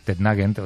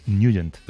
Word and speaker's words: Nugent: Hombre, siempre lo hemos llamado en el barrio Tednagent o Tednagent. – Claro, yo era de Tednagent. – Pero la pronunciación correcta es Nugent.